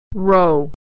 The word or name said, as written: row